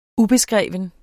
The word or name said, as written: ubeskreven